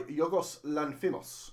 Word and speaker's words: Yorgos Lanthimos: By Yorgos Lanthimos. I think that's how you pronounce that I've seen. – That's how I pronounce it.